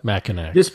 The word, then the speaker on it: Mackinac: I might have pronounced that wrong, folks, but Mackinac. – Mackinac? – Yeah.